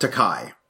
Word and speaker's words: Takei: Takei or Takai? – I think he's pronounced Takai. – Takai. – Yes.